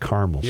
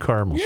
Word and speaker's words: caramel: Some people pronounce it caramel. – I go caramel. – I'm a caramel guy. – I like caramel. It's got an A. It's not a silent A.